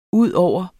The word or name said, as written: udover